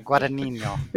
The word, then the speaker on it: Guadagnino: Guadagnino.